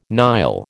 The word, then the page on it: Nile: American English